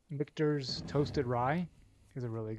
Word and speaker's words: Michter's: The first time Michael has, on the first try, gotten Michter's announced and pronounced correctly.